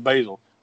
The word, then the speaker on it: Baesel: So as far as starring in this movie, the stars are Nathan Basil, or I guess that's how you say his last name. Basil, B-A-E-S-E-L. We'll go with Basil.